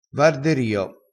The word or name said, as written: varderio